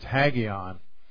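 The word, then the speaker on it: hagion (ἅγιον): And you see how it's pronounced there, it's hagion.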